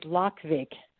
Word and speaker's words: Lokvig: First of all, my last name is Lokvig, but it's a pretty unusual name, so I'll spell it for you. L-O-K.